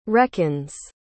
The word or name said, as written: reckons